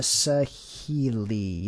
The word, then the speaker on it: Saheeli: I'm sorry, that's um, Saheeli or Sahiri Rai. Was it Saheeli? Uh, Saheeli, yes, I think.